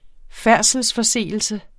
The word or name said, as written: færdselsforseelse